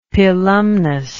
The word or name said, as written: pilumnus